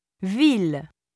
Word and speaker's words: ville: ILL and ILLE are sometimes pronounced il, ville, villa, mille, million.